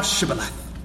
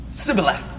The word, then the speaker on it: Shibboleth: If he said, 'No,' then said they unto him, 'Say now Shibboleth.' And he said, 'Sibboleth.'" You see, they could not pronounce it right.